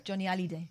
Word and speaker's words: Johnny Hallyday: Johnny Hallyday is pronounced.